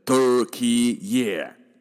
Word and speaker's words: Türkiye: 完整国名叫做 Republic of Turkey，发音哈，新的这个国名呢，从 Turkey 变成了 Turkey Yeah，给几个 Yeah 啦。